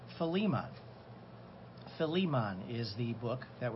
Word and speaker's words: Philemon: The best way I can think of to remember is take the word Philly and just take the emphasis on the Lee part, Philemon.